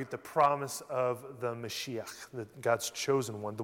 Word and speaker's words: Mashiach: Or in Hebrew, I believe it's pronounced Mashiach, right?